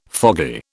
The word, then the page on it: foggy: British English